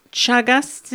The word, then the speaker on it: Chagas: Which are parasites that cause sleeping sickness, Chagas disease, is that the correct pronunciation? – Yes.